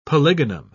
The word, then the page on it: polygonum: British English